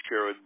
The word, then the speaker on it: Sherrod: I would vote for Sherrod Brown.